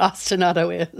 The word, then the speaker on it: ostinato: You have an essay on ostinato. Am I saying that right? Ostinato? – Yes.